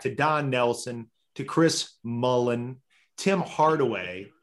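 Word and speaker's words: Mullin: He always pronounced Chris Mullen Mullins and put an S on the end of his name, which drove me crazy.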